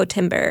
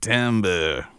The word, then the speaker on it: timbre: So going back to that most misunderstood building block of timbre, am I saying that right? It's not like hi-ho timbre, right? – No, you're nailing it. Timbre. Think of like a French, you know, like eating a baguette and smoking a cigarette.